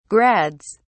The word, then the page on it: grads: British English